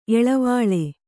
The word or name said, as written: ಎಳವಾಳೆ